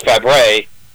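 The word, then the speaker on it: Fabares: I remember when I would play Johnny Angel at the radio station I'd worked at, I pronounced her name Shelley Fabre's, and I should have known better, knowing me and my English precision, and then realized it's Fabre, and I believe Nanette Fabre was her aunt, and she just Americanized the spelling.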